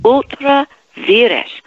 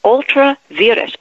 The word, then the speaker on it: ultra vires: Ultra vires. V-I-R-E-S. It's almost like virus, but it's, in fact, sometimes lawyers pronounce it virus, okay? Ultra virus or ultra virus.